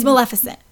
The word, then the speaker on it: Maleficent: The first one is Disney's Maleficent. – Yeah, I don't... – Did I pronounce that right? – Maleficent? – Yeah. And Maleficent. That goes out... – It's Maleficent. – Maleficent. – There you go.